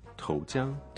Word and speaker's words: 投降: So what's the 降？ – 降就是下降，to descend，或者你知道 parachute 降落伞。 – 嗯，然后呢？如果用在这个词里面，to surrender 就是投降。 – 投降。So that's two second tones.